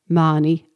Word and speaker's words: Mahoney: One of our readers, Noni Morrison, was on sharing the story of one of her Maani ancestors. Before I say Noreen's letter, I'd just like to say that here in Ireland, in Cork especially, we pronounce M-A-H-O-N-E-Y, Maani. I know some people say Mahoney and such like.